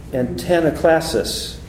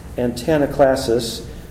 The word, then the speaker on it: antanaclasis: And it is antanaklasis, if I've pronounced it correctly, A-N-T-A-N-A-C-L-A-S-I-S, antanaklasis.